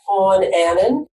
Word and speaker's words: Fawn Annan: Well, LinkedIn, it's strictly at Fawn Annan. People have problems with my name, so it's like a baby deer, F-A-W-N, last name A-N-N-A-N. I know lots of people just don't know how to pronounce that.